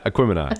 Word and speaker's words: Aquemini: Aquemini. Aquemini. – There you go. You got it. Aquemini. – Aquemini is a combination of Aquarius and Gemini. So pronounce it that way.